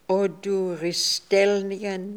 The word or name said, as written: Oduristelnian